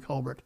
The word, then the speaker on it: Colbert: In an interview over the weekend with uh, Stephen Colbert, for the late show, rest of the family, rest of his family pronounces our last name Colbert. But you see, it's so hoity toity to uh, pronounce it uh, Stephen Colbert.